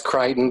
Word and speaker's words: Crichton: It's fun to talk about him because it's pronounced Crichton, but it doesn't look that way when you see it spelled out.